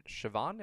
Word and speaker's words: Siobhan: Next up are my notes for your fifth game against Randy and Siobhan. Am I pronouncing that? I think I'm pronouncing that correctly. It's an Irish name.